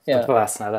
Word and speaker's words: Vipassana: And this guy said he suffered from OCD for a very long time, and he went and did a 10-day silent Vipassana. Did I say it right? – Vipassana, Vipassana, that's it.